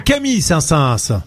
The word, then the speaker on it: Saint-Saëns: Ça se prononce Saint-Saëns ? – Non, c'est Camille Saint-Saëns. – Camille Saint-Saëns, oui.